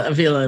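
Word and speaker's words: Ávila: And they actually surprised me and took me to Avila. Is that how you pronounce it? St. Teresa's home? – Avila, it must be.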